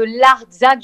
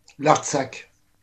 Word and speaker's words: Artsakh: Donc, en effet, qui concerne l'appel des artistes français en faveur de l'Arménie et de l'Artsakh, je ne sais pas si je prononce bien, publié dans le Figaro de... Pardon ? – L'Artsakh. – Oui, l'Artsakh. Donc, voilà.